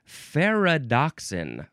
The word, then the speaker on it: ferredoxin: It is ferredoxin. Ferredoxin. Ferredoxin, ferredoxin, here I am. I don't know, that's what my brain went to. It is spelled F-E-R-R-E-D-O-X-I-N. It's, yeah, like Frère Jacques. Ferredoxin, frere.